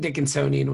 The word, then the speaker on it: Dickinsonian: I mean, if, if you're going to have a Dickinson, Dickinsonian, how do you say that? Dickinson? – I think, Dick, I think Dickinsonian was good.